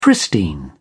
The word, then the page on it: pristine: British English